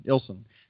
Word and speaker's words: Illson: Illson.